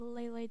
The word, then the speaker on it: Leilei: My name is Lele Dumakilewe. L-E-I-L-E-I space D-U-M as in Mike. A-K-I-L-E-W-E dot.